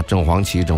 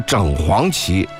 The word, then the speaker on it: 正黄旗: 因为北京啊，好几个地名啊，就是比如说，正黄旗啊、正蓝旗啊，这地名是这个，所以呢，很多人也就念了啊，正黄旗、正白旗。实际上，它正确的读音是整啊，是整黄旗、整白旗，啊，整红旗、整蓝旗啊。